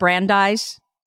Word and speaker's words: Brandeis: Vanessa has a BA from Brandeis University and a master's degree in, is it Brandeis? – Yes.